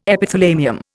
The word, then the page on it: Epithalamium: Indian